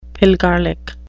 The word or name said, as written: pilgarlic